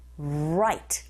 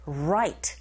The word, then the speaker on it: right: 이때 light 발음하실 때 L 사운드니까 랄랄랄라 light, light 이렇게 발음하시고 R 사운드로 시작하는 단어는 우 하면서 앞으로 내쉬면서 right, right 철자 한 끗 차이인데 완전히 다르죠.